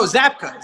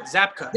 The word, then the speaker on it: Zabka: How do you pronounce William's last name? Zelka? – No, Zabka. Zabka.